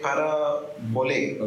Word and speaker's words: parabole: Parable, the original Greek word, it's pronounced as parabole, okay?